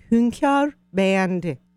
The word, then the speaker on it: hünkâr beğendi: So how do you say it in its actual language? – In actual language, it's hünkâr beğendi. – Wow. Hünkâr beğendi. – Very good.